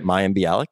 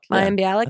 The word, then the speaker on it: Mayim Bialik: This is, if you guys can't already tell from the title, we have Mayim Bialik on, which I always think I'm saying her name wrong, which I'm sure is just everyone's par for the course. – I think that's right. Mayim Bialik. – Mayim Bialik. – I think that's how you pronounce it.